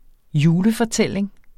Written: julefortælling

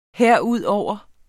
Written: herudover

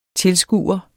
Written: tilskuer

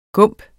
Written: gump

